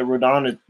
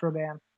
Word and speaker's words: Rodan: And yes, it is pronounced Rodan. – Rodan, yeah, that's what I'm saying.